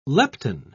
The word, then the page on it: lepton: British English